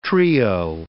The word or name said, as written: trio